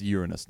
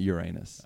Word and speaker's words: Uranus: It's pronounced Uranus, not Uranus.